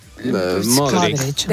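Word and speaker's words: Modrić: ¿Se dice Modric o Modric? – Modric. – Depende de cómo quieras ya pronunciar, pero vamos, aquí Luca Modric.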